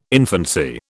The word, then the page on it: infancy: British English